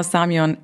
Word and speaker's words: Samion: Äh, Samion, wird das deutsch ausgesprochen? – Genau, Samion.